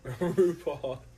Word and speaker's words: RuPaul: RuPaul. – The drag queen? – Rhymes with New Paul. – No, it doesn't. It really doesn't.